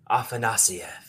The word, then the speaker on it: Afanasyev: Kyle, first of all, you say his name so beautifully, Afanasyev.